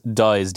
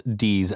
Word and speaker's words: dies: Pronunciation of the Wannadies is Dies, D-Y-E-S, not D's as in D-E-E-S.